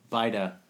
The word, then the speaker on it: Bajda: This time we have a special guest, Mr. My name's Matthew Bajda. – Matthew Bajda. – Spelled weird, but... Yeah. – I know. I was like, Bajda? Do we pronounce the J? – I get Bajda.